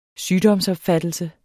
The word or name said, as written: sygdomsopfattelse